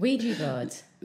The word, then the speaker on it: Ouija: Ouija board.